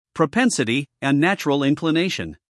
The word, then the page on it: propensity: prəˈpensəti